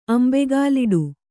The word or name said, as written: ಅಂಬೆಗಾಲಿಡು